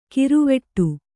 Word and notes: ಕಿರುವೆಟ್ಟು: kiru veṭṭu

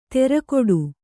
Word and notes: ತೆರಕೊಡು: terakoḍu

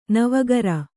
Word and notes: ನವುಕರಿ: navukari

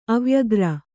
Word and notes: ಅವ್ಯಗ್ರ: avyagra

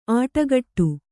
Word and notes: ಆಟಗಟ್ಟು: āṭagaṭṭu

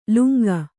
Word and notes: ಲುಂಗ: kannada

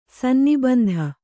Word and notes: ಸನ್ನಿಬಂಧ: kannada

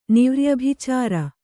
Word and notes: ನಿವ್ರ್ಯಭಿಚಾರ: kannada

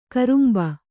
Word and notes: ಕೊರಂಬೆ: Kannada

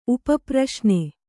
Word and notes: ಉಪಪ್ರಶ್ನೆ: kannada

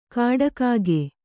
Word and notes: ಕಾಡಕಾಗೆ: kannada

